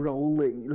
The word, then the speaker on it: Rowling: Oh, Rowling, la Rowling.